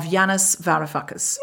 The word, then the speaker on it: Yanis Varoufakis: And we've just watched an episode of an amazing new documentary series, In the Eye of the Storm, The Political Odyssey of Yanis Varoufakis.